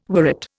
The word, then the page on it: worrit: Indian